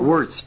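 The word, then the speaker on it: Wuest: W-U-E-S-T. I don't know how to pronounce it. – Wurst.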